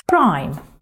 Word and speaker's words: rhyme: Rhyme. Запомни, H не читается.